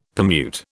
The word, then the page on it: permute: British English